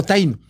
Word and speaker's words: Taíno: En Puerto Rico diría ese aborigen que tenemos en el fondo, ese itaíno, ¿no? ¿Cómo se dice itaíno, no? O taíno.